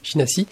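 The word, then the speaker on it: Şinasi: Alors là aussi, je vais encore prononcer mal, la Sinasi, qui est important aussi. Sinasi, oui, tout à fait, Sinasi.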